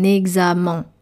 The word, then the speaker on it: examen: E-X-A-M-E-N. Pourquoi c'est difficile à prononcer? Eh bien, le E-N final peut être assez compliqué. On a envie de lire un examen. Un examen. Mais pas du tout. C'est un examen, comme matin.